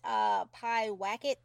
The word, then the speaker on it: Pyewacket: Have you seen Pie Wacket? Am I pronouncing it wrong? – No.